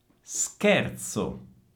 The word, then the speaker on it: scherzo: Scherzo, scherzo. Attenzione, CH sono duro, quindi sker e poi zo. Stessa famosa Z di pizza che già sapete pronunciare, quindi scherzo, scherzo.